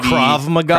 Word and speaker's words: Krav Maga: Krav Maga.